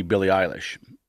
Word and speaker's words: Billie Eilish: And they're flying somebody to Omaha to see Billie Eilish.